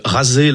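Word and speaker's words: Razeh (غزة): Razeh, la prononciation arabe de Gaza.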